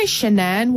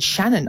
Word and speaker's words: Shanann: And she also wants us to know about the Chris and, you say Shanann Watts. I've always said Shannon. I didn't know how to pronounce her name.